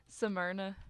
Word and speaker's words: Smyrna: Smyrna.